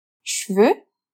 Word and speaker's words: cheveux: E atenção aqui para a pronúncia. Cheveux é uma daquelas palavrinhas traiçoeiras e que, ao menor descuido, pode ser confundido com o plural de cavalo.